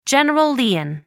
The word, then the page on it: general lien: American English